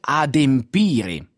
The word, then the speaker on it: adempire: si può pronunciare in due modi, si può dire adempiere qualcosa oppure adempire qualcosa,